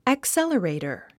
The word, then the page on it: accelerator: əksélərèitər; アクサラレイタァ